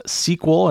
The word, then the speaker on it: SQL: We talk about SQL, sometimes pronounced SQL, and that is a very popular database language.